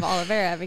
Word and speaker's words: Olivera: Your name is spelled M-E-R-A? – Yes. – So glad you told me that. – Well, and then I left off the ending of Olivera because, you know, that silent I.